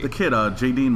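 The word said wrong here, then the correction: Jaeden: Martell. – Jaden.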